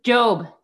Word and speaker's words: Joob: Yes, it's Job, like Job in the Bible.